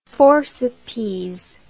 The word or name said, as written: forcipes